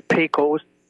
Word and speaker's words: PECOS: I said, spell that, P-E-C-O-S. It's actually PECOS, as in PECOS bill. Not part of the PECOS system, and beginning in 2014, Obamacare requires doctors to be registered with the PECOS system or PECOS system in order to prescribe for people over the age of 65 or who are on Medicare.